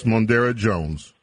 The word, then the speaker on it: Mondaire Jones: I'd like to tell you, yesterday we were talking about my congressman, first-term congressman by the name of Mondaire Jones. Hopefully I pronounce his name right. – Yes, Mondaire Jones.